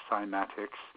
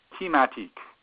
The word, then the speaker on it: cymatics: that's spelled J-E-N-N-Y. And cymatics has been anglified to be with a C and a soft sound, cymatics.